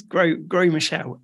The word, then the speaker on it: Gros Michel: G-R-O-S. Yeah. Michel. – You don't pronounce the S. I'm pretty sure it's French. It's Gros Michel.